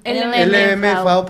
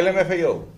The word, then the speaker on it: LMFAO: El, LMFAO. Ah, LMFAO.